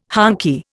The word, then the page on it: honky: Indian